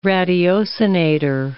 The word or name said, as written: ratiocinator